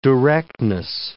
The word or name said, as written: directness